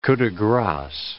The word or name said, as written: coups de grace